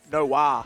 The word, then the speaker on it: Noe: And one of them is Noe, is it? N-O-E? No? How do you say her name? – Noah.